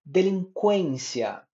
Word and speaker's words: delinquência: Segue algumas palavras em suas diversas formas que devemos pronunciá-las com U, que antes teria o sinal gráfico do trema, mas hoje não temos. Que antes era um, uma super ajuda. Delinquência, delinquente, frequentar, frequentemente, equidistância, equilateral, equilátero, grandiloquência, tranquilamente, tranquilo, inconsequente, inconsequência, frequência, ambiguidade, ambíguo, linguística, neurolinguística, multilingue, multilinguismo e por aí vai.